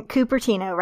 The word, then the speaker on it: Cupertino: We don't have to get like super deep into it, but take me through the basics of like little Karin being raised in Cupertino, right? Is that how you say it? – Yes, it is.